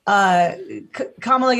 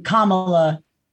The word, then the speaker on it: Kamala: And in Ms. Marvel, the show, Kamala gets her, Okay. So the vice president pronounces her name differently, and I'm getting them mixed up constantly because it's Kamala Khan, which is different from Kamala, the vice president.